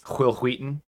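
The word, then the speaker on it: Wil Wheaton: I'm pretty sure you mispronounced it. It's Will Wheaton.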